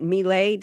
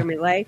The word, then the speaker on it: Milei: Well, one of the things that we can consider doing is what the new Argentinian president Milei, did I say his name right? – Mm-hmm. Yeah. – Milei.